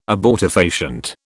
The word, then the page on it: abortifacient: British English